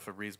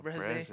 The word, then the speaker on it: Febreze: Pronouncing it wrong this whole time. – Maybe. – Febreze. But I mean, you would think they'd spell it breeze with two e's, because mm-hmm. – it's like fresh, like a breeze, you know. – Mm-hmm. Yeah. – But they fucking say they pronounce it that way on the commercials.